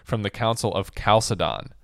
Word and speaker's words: Chalcedon: I was asking John before the show how he pronounced that word, because I've heard it three different ways. I've heard it Chalcedon. – We both pronounce it Chalcedon. – Yeah, which that means that that's the correct one.